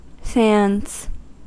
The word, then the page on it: sands: American English